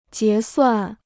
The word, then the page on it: 结算: jié suàn